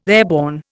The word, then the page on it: airborne: Indian